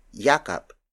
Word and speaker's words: yakap: This lesson is brought to you by the Tagalog word yakap. Yakap. Yakap. Yakap is spelled as Y-A-K-A-P.